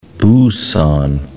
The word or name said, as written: Busan